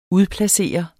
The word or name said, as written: udplacere